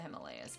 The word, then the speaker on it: Himalaya: Himalayas. – Way I figure it, we're somewhere in the Himalayas Mountains. That's pronounced Himalaya.